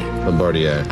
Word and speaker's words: Bombardier: Bombardier.